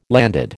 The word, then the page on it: landed: American English